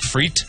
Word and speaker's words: frites: Did you know the French don't even pronounce the S? They just say steak frites? – Yeah.